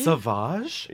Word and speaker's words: Sauvage: I've been saying Savage. – You are teaching us so many things. – Okay. Don't quote me on this. Maybe, I don't know. – I love that though, if that but is right. – I've heard Rihanna say Sauvage.